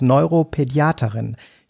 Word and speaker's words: Neuropädiaterin: Und Sie sind weiter Privatdozentin, Dr. Med, Diplompsychologin, Kinder- und Jugendärztin und jetzt kommt so ein ganz schwieriges Wort, Neuropädiaterin. Ich hoffe, ich habe das richtig ausgesprochen. – Genau. Oder Kinderneurologin ist das Gleiche.